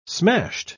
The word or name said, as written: smashed